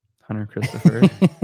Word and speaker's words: Hunter Christopher: Hunter Christopher. – perfect. With a short C.